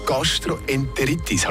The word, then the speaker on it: Gastroenteritis: Gastroenteritis, habe ich das richtig ausgesprochen? – Perfekt.